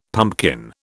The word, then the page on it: pumpkin: British English